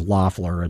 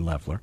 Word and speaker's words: Loeffler: And everybody says Loeffler. It's actually Loeffler. It's a German thing.